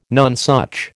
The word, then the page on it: nonesuch: American English